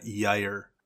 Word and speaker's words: Gyre: Matt, I believe the genesis of that word is Swedish, and it's pronounced with a Y. It's Infinite Gyre.